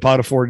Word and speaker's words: Potiphar: Potiphar, yep.